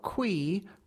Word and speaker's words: Quee: It was funny because Daniel Boulud is Daniel Boulud, but everybody's saying hello to Charles, a chef named Quee rhymes with Twee, who runs Gabriel's business, said recently.